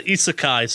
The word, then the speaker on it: isekai: I feel like the isekai stuff, that might be a appropriate one for the list, though.